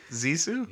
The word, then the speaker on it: Zissou: Is that how you pronounce it? Zissou? – Yeah. Yeah.